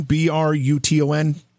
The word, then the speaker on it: Bruton: Daniel, I want to find out more about is it the Bruton, Bruton, Parish Church? Yes. Is that how it's pronounced? – That's right.